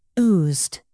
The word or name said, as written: oozed